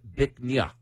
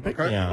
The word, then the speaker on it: Bich Nga: It's spelled B-I-C-H-N-G-A. It's pronounced Bích Nha, which loosely translates to beautiful jade. – Oh, Bích Nha.